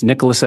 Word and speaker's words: Nicholas: My name is Nicholas Edwards, N-I-C-H-O-L-A-S, Edwards, E-D-W-A-R-D-S.